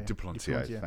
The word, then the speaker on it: Duplantier: Duplantier.